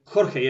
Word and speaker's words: Jorge: Jorge, yeah, it's like Spanish name, Jorge. – Yeah. – It's Spanish pronunciation of this name, Jorge.